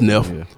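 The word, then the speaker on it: Nef: He got a song with E-40 and Nero the Fifth is banging. – Really? – Neff the Pharaoh, whatever. How you say his name? Yeah, it's Neff.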